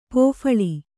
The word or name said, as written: ಪೋಫಳಿ